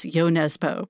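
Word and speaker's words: Jo Nesbø: And I understand from the publisher Knopf that it's Yo Nesbo, but I feel like it doesn't sit well with me. I'm just going to do the American thing and mispronounce.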